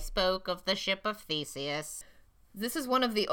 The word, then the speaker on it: Theseus: Ship of Theseus, which I have talked to... Theseus? I said that right.